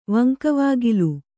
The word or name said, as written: ವಂಕವಾಗಿಲು